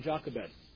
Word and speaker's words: Jochebed: Amram and Jochebed. And yes, I am following the King James pronunciation.